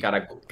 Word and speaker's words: Karakuri: Karakuri. – Sabéis que la R en japonés se pronuncia como muy suave, ¿no?